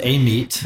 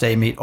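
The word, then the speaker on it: Amite: live there, it's pronounced Amit, or a lot of folks would call it Amit, okay. which is really probably the phonetically correct answer. But some folks will even call it Amit. – Amit. – So okay. you get all three.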